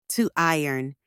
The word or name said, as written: to iron